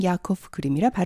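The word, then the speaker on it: Jakob Grimm: 독일어로는 야코프 그림이라 발음합니다.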